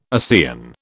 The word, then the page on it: ASEAN: British English